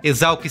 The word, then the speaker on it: ESALQ: Exalc se escreve com S e Q mudo no final, tá? E-S-A-L-Q.